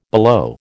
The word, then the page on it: below: American English